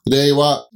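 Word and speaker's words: Reiwa (令和): Reiwa. – We say Reiwa because we like the R sound. Reiwa. – Yeah, maybe it's more like an L. – Ah, okay. Reiwa. – This time, this Reiwa Gengo was taken from the Japanese Manyoshu.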